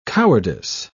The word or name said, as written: cowardice